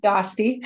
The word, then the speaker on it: Dostie: Dostie.